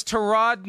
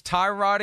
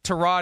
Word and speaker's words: Tyrod: It's Tyrod again now, I, I think until it's Tarot again. But but But I try to respect how everyone, however anyone prefers their name to be pronounced. Tyrod Taylor, now number 34 on the Chris Sims quarterback countdown, ahead of the guy that he's trying to hold off in Justin Herbert.